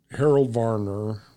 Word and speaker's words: Harold Varner: And so the thing that Harold Varner, right...